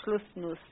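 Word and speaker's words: Schlusnus: Yeah, Schlussmann. – No, I don't think there's a man on the end. I think it's just Schlussnuss. – Oh, I somehow added the man on the end.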